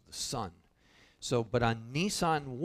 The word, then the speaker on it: Nisan: So the name of the month that the Passover was to be celebrated on was the month of Nisan, not like the car, okay, but it's pronounced the same way.